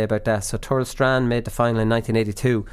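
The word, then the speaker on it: Tourlestrane: Tourlestrain, I'm pronouncing that right, Connor. I am.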